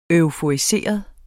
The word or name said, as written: euforiseret